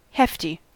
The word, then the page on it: hefty: American English